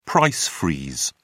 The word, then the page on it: price freeze: British English